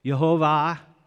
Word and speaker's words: Jehovah: Jehovah is, according to him, is the proper way to pronounce that name.